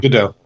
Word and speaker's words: Gadot: She's Gal Gadot, however you say her name. – Gadot.